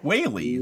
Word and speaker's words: Whaley: Frank Weehole. – Frank Weehole. – Frank Whaley. It's just Whaley.